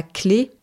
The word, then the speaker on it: Clay: Donc par exemple, on retrouve des lieux-dits qui s'appellent la haie ou la clé. Clé, ça s'écrit C-L-A-Y.